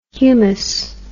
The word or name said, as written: hoummos